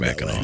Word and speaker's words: Mackinac: Oh, it's Mackinac? – That's right. It's pronounced Mackinac.